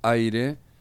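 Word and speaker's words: Ahyre: Bueno, vamos a escuchar ahora al grupo Aire, que se escribe A-H-Y-R-E, y una canción que se llama Identidad, ¿no, Daniel?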